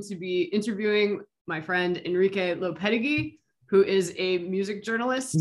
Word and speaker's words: Lopetegui: Enrique Lopetegui.